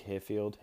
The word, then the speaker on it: Hearfield: If you just type my name in, Luke Hearfield, H-E-A-R, Field.